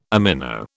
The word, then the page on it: amino: British English